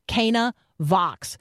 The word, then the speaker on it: Canavox: And so it, Cana, like, right, the wedding at Cana, that's what's in view here. Cana Vox.